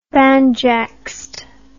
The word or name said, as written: banjaxed